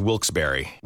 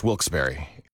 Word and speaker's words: Wilkes-Barre: Now, I had heard that it was supposed to be pronounced Wilkes-Barre. Wilkes-Barre Scranton's 97.9X. The only way to pronounce Wilkes-Barre.